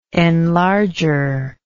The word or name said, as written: enlarger